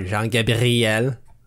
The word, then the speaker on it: Jean-Gabriel: You got to roll that R. Jean-Gabriel.